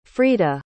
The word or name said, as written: freda